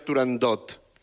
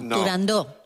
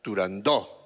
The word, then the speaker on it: Turandot: No se dice, no se debe pronunciar Turandot. – No, Turandó. – La ópera se titula Turandó.